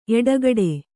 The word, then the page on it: ಎಡಗಡೆ: eḍagaḍe